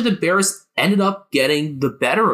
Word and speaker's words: Barras: Tom Barras, I believe it actually is. I'd been vacillating between that and Barras on the pronunciation, but apparently he pronounces it like the final two syllables of embarrass.